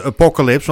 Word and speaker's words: Apocalypse: Ja, het is gewoon Apocalypse.